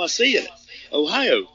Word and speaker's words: Ohio: Ohio. That's how you say it.